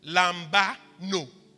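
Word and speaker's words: lambano (λαμβάνω): You know, the word in Isaiah 53 that he took is a Greek word, lambano, just like I pronounced it, lambano.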